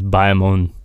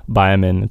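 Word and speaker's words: Bayamón: one of the places is Bayamon. Bayamon, it's spelled like B-A-Y-A-M-O-N.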